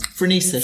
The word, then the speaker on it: Phronesis: Um, how do you pronounce this? Francis? Phronesis. – Phronesis. Mm. Thanks.